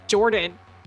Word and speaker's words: Jordan: My name is Jordan. J-O-R-D-A-N.